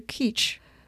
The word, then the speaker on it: kitsch: 这个词其实大家都知道叫媚俗，它是一个德语词，很多人翻译成客气，因为德语就发音就是 kitsch，我们就经常说德语，就说哎呀很 kitschig，就是太做作，那种扭捏做作 kitschig。